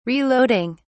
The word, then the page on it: reloading: British English